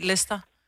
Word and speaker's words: Leicester: Altså, der er jo bare nogle ting, du ikke udtaler. Det staves jo også sådan noget Leicester. – Jo, jo. – Men bliver bare udtalt Leicester.